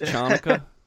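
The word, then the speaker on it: Chanukah: It's like go to temple on the high holidays and celebrate Hanukkah, but like, you know, who gives a shit about the rest? – It's pronounced Chanukkah.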